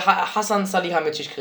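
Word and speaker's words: Hasan Salihamidžić: Und äh, Hassan Salihamidzic kreiert. Ja, richtig gut ausgesprochen.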